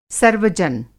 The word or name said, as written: सर्वजन